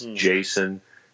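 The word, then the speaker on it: Jayson: Jason W. Smith on both of those. J-A-Y-S-O-N-W Smith.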